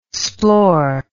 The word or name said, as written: splore